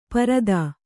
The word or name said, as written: ಪಾರುಧಿ